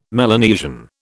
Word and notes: Melanesian: British English

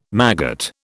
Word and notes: maggot: British English

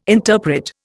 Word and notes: interpret: Indian

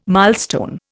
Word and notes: milestone: Indian

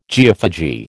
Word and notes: geophagy: American English

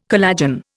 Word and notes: collagen: Indian

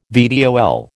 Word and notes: VTOL: American English

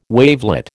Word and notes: wavelet: American English

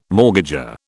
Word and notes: mortgager: British English

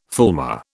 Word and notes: fulmar: British English

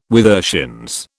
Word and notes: withershins: British English

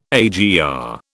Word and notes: AGR: British English

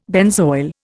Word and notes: benzoyl: Indian

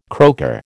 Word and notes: croaker: American English

